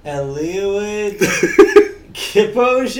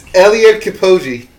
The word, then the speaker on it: Eliud Kipchoge: Had a record beaten this week as a man named Eliud Kipchoge? – Yes, I knew you were going to butcher this. – Okay. – It's Eliud Kipchoge. Kipchoge. Eliud.